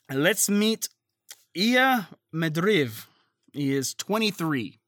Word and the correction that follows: Ilya: And I could be saying that wrong. It's Ilya is his first name. – Ilya, okay.